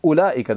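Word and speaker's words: Ulaika: Like in Ula'ika, the Waw is added, right? Nobody reads it as Ula'ika, right? Or Uwala'ika. It's just Ula'ika, the Waw is silent.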